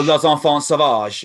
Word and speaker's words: L'Enfant Sauvage: I listened to, I'm going to say this wrong, L'Enfant Sauvage. – Oh, L'Enfant Sauvage, yeah.